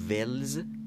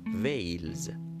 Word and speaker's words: Wales: اما ولز نیست ویلز تلفظ صحیح انگلیسیش